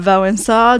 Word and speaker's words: Wałęsa: Wensa, arrive au pouvoir. – Je le prononce avec mon horrible accent français, Lech Euh, Wałęsa,